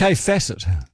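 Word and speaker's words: Kaffe Fassett: Kefacet. – Yeah, yeah. So it's spelled I just wanted to, for everyone, K-A-F-F-E, Kafe, Facet, F-A-S-S-E-T-T.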